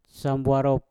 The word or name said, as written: sambwarot